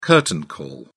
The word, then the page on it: curtain call: British English